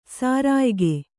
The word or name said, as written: ಸೆರೆಯಾಗು